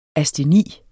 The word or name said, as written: asteni